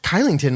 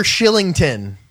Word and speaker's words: Kylington: I was thinking, man, that Kylington must not do much out there. You'd never say his name. Calgary Flames defenseman Oliver Shillington.